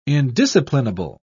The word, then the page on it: indisciplinable: British English